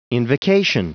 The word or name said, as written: invocation